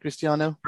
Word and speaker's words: Cristiano: Did I say your name right, Cristiano? – Perfect, baby.